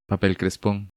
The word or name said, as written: Papel Crespon